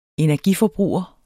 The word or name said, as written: energiforbruger